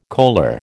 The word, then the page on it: coaler: American English